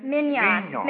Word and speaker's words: Mignon: from um, um, I can spell it, but I, I never can pronounce it. It's M I G O N. Uh, something like that, Migon or Mignon. – Mignon.